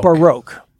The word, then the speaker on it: Baroque: And you say Baroque. – Baroque. – B-O-R? – B-A-R-O-Q-U-E.